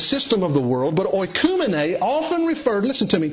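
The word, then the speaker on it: oikoumene (οἰκουμένη): a word pronounced oikoumene.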